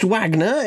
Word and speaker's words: Wagner: It's pronounced Wagner. It's anglicised.